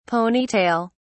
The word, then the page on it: pony tail: British English